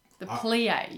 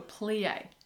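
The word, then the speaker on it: plié: You're going to have to do the plie. You do a plie.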